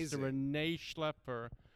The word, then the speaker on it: Rene Schlaepfer: I think I said that correctly. – You said it perfectly. – First time. – Amazing. – Pastor Rene Schlaepfer from Twin Lakes Church, who I've listened to for hours on end, and always gotten some great stuff.